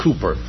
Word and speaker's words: Cowper: It's really probably pronounced Cooper.